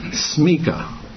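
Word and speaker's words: smikhah: I'm pronouncing it smika. Smika.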